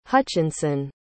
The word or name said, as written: Hutchinson